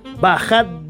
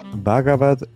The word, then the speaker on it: Bhagavad: Este que es, y eh, ustedes seguramente nuestros invitados eh, lo conocen muy bien, Bhagavad Bad Gita, y si lo estoy pronunciando bien, por favor, me corrigen. – Bhagavad Gita.